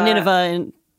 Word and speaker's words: Nineveh: Nineveh in American English, yeah.